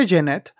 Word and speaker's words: Jennette: Jeanette, porque se pronuncia Jeanette,